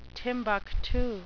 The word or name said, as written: timbuktu